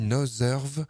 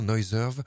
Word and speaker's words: Noiserve: Et puis juste avant, on a eu Noiserve. Ça s'écrit comme Noise avec RV à la fin, Noiserve.